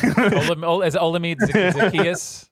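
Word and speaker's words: Olamide Zaccheaus: Alameda Z. – Is Olamide Zacchaeus? Yeah.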